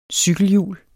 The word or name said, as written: cykelhjul